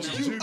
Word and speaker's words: Joop: It was, it's a yoke. Is that how you pronounce it? J-O-O-P, but I think Juke. Oh, Juke. Oh, Juke.